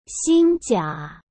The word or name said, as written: xinjia